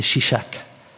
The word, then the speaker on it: Shishak: Salomon chercha à faire mourir Jéroboam, alors Jéroboam se leva, prit la fuite en Égypte au prix de Shirak. C'est la fin de son de prononciation israélienne, non c'est Shishak, roi d'Égypte.